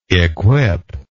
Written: equipper

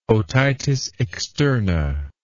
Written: otitis externa

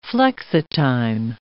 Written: flexitime

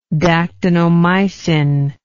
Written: dactinomycin

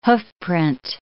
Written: hoofprint

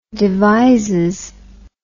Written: devizes

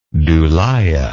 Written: dulia

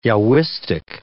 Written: Yahwistic

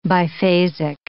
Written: biphasic

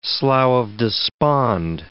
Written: slough of despond

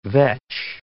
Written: vetch

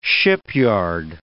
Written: shipyard

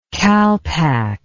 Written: kalpak

